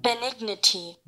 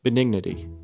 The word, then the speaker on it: benignity: Benignity. – Benignity. – Benignity. – Okay. Yeah. So it sounds like dignity, but it's benignity. On her deathbed, the fortitude and benignity of this best of women did not desert her.